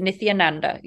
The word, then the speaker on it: Nithyananda: Nithyananda. – That's it. Nithyananda.